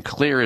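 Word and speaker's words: Xlear: And Clear is spelled with an X, X-L-E-A-R, Clear Rescue Nasal Spray.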